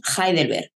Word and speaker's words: Heidelberg: en pronunciación española diríamos Heidelberg.